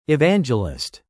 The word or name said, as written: evangelist